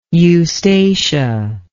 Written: eustacia

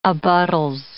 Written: abuttals

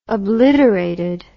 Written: obliterated

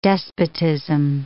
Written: despotism

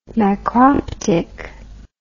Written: macroptic

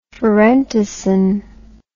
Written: pherentasin

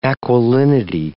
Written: aquilinity

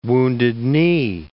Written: Wounded Knee

